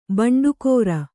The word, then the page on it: ಬಂಡಿಕಾರ: kannada